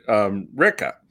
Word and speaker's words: Ricca: Do you pronounce your name Ricca or is it Ricca? – That's a great question, because it's supposed to be, I mean, we pronounce it Ricca.